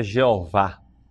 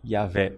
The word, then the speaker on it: Yahvé: Jeová, né? Jeová. A pronúncia correta é Yahvé.